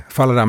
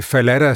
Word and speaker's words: Fallada: Der er en hest i et af Grimms eventyr, der hedder Fallada, men det er kun med et L, så det er egentlig Fallada, som Sebastian Klein jo også udtaler det, når han læser det op, og det lyder helt forkert i mine ører, men med to L'er, der tænker man, det er nok en måde at gøre lidt grin med jurasprog.